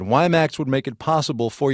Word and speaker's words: WiMAX: Today is the 7th of May, and just so we're clear here, it's WiMAX. W-I-M-A-X is how you spell it.